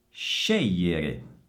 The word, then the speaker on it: scegliere: Scegliere. Scegliere. Occhio all'SC e GL che è simile al LH del portoghese.